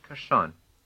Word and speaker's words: kershon: Remember that kershon is one of the few words where the stress falls on the second syllable.